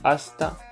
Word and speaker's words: hasta: Здесь можете увидеть, что там hasta, hasta luego. Тут hasta, когда она не в конце, а в середине, да, читается как s.